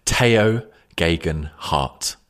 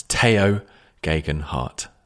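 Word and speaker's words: Tao Geoghegan Hart: Well, I'm just going to interrupt the podcast to tell you that the correct pronunciation we have discovered is Tao Gegenhardt. That's Tao Gegenhardt. So I guess Andy had it half right and I had it half right.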